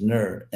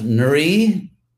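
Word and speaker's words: nṛ: So you can either say Nara, or there's sort of a quick version, which is just Nur, N in the vowel R, which devotees sometimes pronounce Nuri, which Nuri Hari, which is, you know, sounds nice, kind of rhymes, Nuri Hari. It's just not what the name is.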